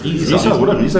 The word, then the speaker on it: Riesa: Riesa, genau.